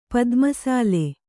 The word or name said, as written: ಪದ್ಮಸಾಲೆ